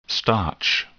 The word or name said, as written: starch